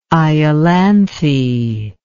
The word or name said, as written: Iolanthe